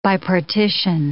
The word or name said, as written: bipartition